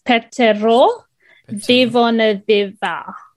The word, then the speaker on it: Petero Civoniceva: Yeah, so it's Petero Civoniceva. So the C is pronounced like the. TH.